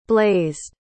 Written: blazed

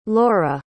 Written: lora

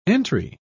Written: entry

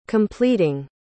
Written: completing

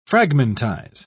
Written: fragmentize

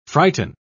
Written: frighten

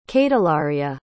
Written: cadelaria

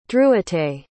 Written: drewite

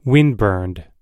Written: windburned

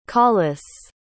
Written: collis

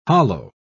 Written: hollo

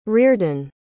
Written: riordan

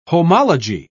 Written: homology

